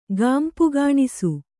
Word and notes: ಗಾಂಪುಗಾಣಿಸು: gāmpagāṇisu

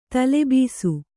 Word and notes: ತಲೆಬೀಸು: tale bīsu; kannada

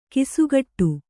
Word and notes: ಗಸಿಗಟ್ಟು: kannada

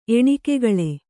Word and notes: ಎಣಿಕೆಗಳೆ: Kannada

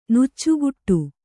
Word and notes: ನೆಚ್ಚುಗೆಡು: neccugeḍu

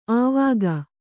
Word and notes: ಆವಕ್: Kannada

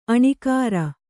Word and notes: ಆಣಿಕಾರ: āṇikāra

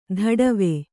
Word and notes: ಧಡವೆ: dhaḍave

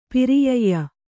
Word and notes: ಪರ್ಯಯ: paryaya

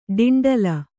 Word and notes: ಡೆಂಡೆಲಿ: ḍeṇdeli

